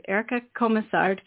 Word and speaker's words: Erica Komisar: We have Erica Komisar.